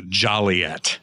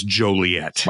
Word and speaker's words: Joliet: In fact, believe it or not, there is still it's a very antiquated, old, outdated ordinance in the city that prohibits the pronunciation of Joliet and says there's only one appropriate pronunciation, and that is Joliet.